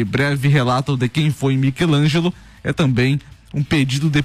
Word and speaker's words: Michelangelo: Fala, e agora, depois do erro da pronúncia do nome dele, eu preciso dizer quem é Michelangelo e não Michelangelo, como foi dito no bloco anterior.